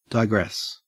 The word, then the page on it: digress: Australian English